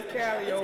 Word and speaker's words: Calliope: The word is Burgundy and Calliope. It is Calio.